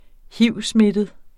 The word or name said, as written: hivsmittet